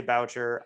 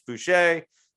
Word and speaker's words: Boucher: I always say Boucher. I know it's Boucher.